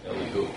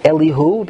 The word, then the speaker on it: Elihu: But that would also explain why he had a brother of Eliyahu. Am I pronouncing his name correct? – Elihu. – Elihu, because Elihu was then later, after this Maisa.